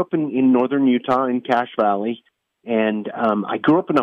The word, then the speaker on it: Cache Valley: It's pronounced Cache Valley. The word cache in it, it's spelled different. It actually comes from the French trappers, cache, where they used to hide their their supplies, and it was called a cache.